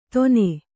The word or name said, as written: ತೊನೆ